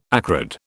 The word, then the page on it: acrid: British English